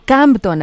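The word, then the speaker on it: Compton: Campton, así se pronuncia.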